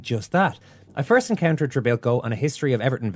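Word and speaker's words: Trebilcock: Imagine my juvenile delight when I later saw his name in print and discovered that the man whose name was innocuously pronounced Trebilco was in fact spelled Treblecock. From that day hence, he was inevitably known to me as Mike Treblecock.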